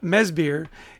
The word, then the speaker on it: Madsbjerg: And so even though it's uh, Mesbier, it's spelled M A D S B J E R G. So don't try to sound it out.